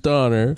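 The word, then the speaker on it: Döner: I've been told it should be pronounced doner.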